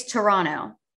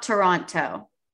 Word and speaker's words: Toronto: Also, while we're here, it's Toronto. It's not Toronto. The second T is silent. It's like Atlanta.